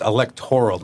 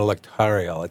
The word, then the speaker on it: electoral: It's electoral, not electorial. It's a difficult word to pronounce.